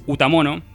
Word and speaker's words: utamono: Una es utamono, se dice así, se pronuncia así, que es el estilo cantado.